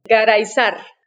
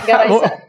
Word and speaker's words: Garayzar: Entonces, cuando pronuncias un apellido para no matarlo, cuando hago la introducción, ¿cómo pronuncias el apellido? – Garaizar. – Ok. Garaizar.